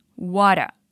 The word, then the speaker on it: what a: E é bem comum pronunciar assim, water.